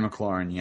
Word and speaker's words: McLaurin: I might pronounce this wrong, but it's McLaren. Is he the bloke with the receiver? – Terry McLaurin, yeah. – Yeah, McLaurin. He's playing some good football.